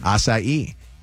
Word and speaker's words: acai: For acai bowls. A-H-S-I-G-H-E-E. Acai.